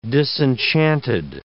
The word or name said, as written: disenchanted